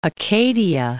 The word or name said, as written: Acadia